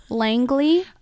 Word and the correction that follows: Laneige: Oh, Laneige.